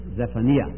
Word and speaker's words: Zephaniah: Is that the way you pronounce it in English? We say Zephaniah.